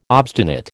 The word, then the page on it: obstinate: American English